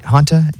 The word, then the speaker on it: Hanta: Hantus? – Yeah. Hanta, H-A-N-T-A. – Oh, I thought there was an S, my bad.